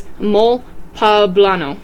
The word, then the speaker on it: poblano: The traditional dish eaten in the town of Puebla on their big holiday is mole poblano, pronounced with the emphasis on the second syllable like guacamole. So, like, poblano.